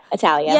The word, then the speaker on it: Attaliah: Yeah, Attaliah.